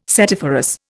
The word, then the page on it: setiferous: Indian